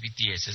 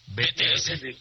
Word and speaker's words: BTS: No es BTS, es BTS. – ¿Es el mexicano? – BTS. – Pronunciado en español.